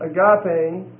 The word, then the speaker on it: agape: You have agape, A-G-A-P-E, long E. That's a noun. Okay, thank you. And then agapao would be the verb, A-G-A-P-A-O.